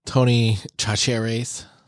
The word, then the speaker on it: Tony Chachere's: Yeah, Tony Chachere's. – Is that how you say it? – That's not how you say it. Tony Chachere. – Tony Chachere's.